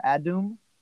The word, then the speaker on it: Edom: and so the word Adam or Adum, A-D-U-M, right, is kind of another word or another way to pronounce Edom, which is a neighbor of Israel, Edom.